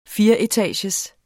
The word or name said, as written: fireetagers